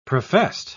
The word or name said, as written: professed